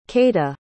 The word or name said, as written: keita